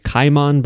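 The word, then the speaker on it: Kaiman: So anyway, this character's name is pronounced Cayman by the Japanese characters.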